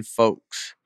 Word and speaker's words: folks: Why do y'all keep pronouncing the L in folks? I'm from Mississippi, and I even know not to do that.